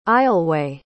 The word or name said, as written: aisleway